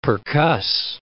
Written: percuss

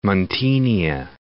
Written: Muntenia

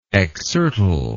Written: exsertile

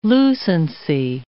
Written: lucency

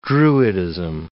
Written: druidism

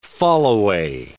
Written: fallaway

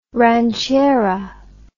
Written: ranchera

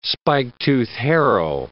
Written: spike-tooth harrow

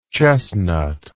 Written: chestnutty

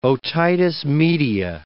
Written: otitis media

